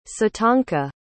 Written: sitanka

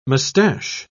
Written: moustache

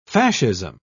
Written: fascism

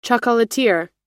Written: chocolatier

